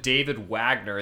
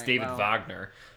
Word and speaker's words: Wagner: I mean, if you pronounce it David Wagner, yeah, maybe that sounds American, but it's David Wagner.